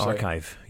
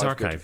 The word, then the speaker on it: arXiv: So it's spelled A-R-X-I-V, but the X is a chi, like a Greek chi letter. – Oh. – So it's archive.